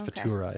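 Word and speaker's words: Furuta: I think I had the R in the wrong spot of her name. It's Futura, I think.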